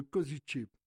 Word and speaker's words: Košice: La prononciation de notre ville, c'est Košice.